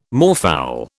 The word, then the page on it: moorfowl: British English